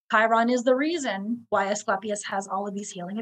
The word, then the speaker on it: Asclepius: So anyways, a fucus, his Greek name is Asclepius. Am I pronouncing it correctly? Asclepius. – Yeah. Mm-hmm.